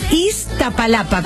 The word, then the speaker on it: Iztapalapa: Busquémonos cómo se dice el lugar bien. El lugar ese, dale. – Esta palata. – ¿O es esta palapa? A ver. – Esta pa. – ¿De dónde son ellos? – Esta palapa. – ¿Cómo se escribe? – Iztapalapa con Z. Iztapalapa.